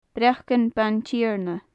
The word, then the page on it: breacan baintighearna: gaelic